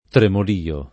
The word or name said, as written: tremolio